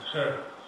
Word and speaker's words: Hur: Chrissy told me how to pronounce that. Hur. H-U-R.